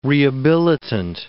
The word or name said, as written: rehabilitant